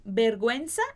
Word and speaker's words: vergüenza: E se eu quiser fazer esse som de gui, eu vou colocar também a diéresis em cima desse U, por exemplo, como na palavra pinguino. Então, vergüenza é vergonha e pinguino é pinguim.